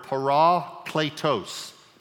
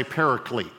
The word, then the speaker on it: parakletos: comes from a Greek word, parakletos. I'm saying it to emphasize both parts of the word that makes up the word, all right? Parakletos. You've heard people say paraclete. That's an English pronunciation of it.